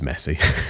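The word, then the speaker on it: Messe: But if anyone's ever been to, how do you pronounce it? Is it Messi? The Messi venue, which is the, it is Messi.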